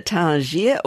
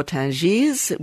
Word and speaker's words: Tangier: So Tangier or Tangiers, whichever way you like to pronounce it, is done both ways, with or without the S, is a Moroccan port on the Strait of Gibraltar that's been a strategic gateway between Africa and Europe since Phoenician times.